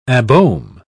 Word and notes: abohm: British English

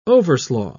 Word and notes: overslaugh: British English

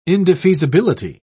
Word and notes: indefeasibility: British English

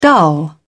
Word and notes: dull: British English

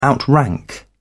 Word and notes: outrank: British English

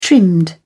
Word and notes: trimmed: British English

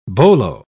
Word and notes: bolo: British English

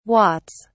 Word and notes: watts: British English

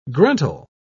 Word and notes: gruntle: British English

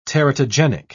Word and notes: teratogenic: British English

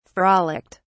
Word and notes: frolicked: British English

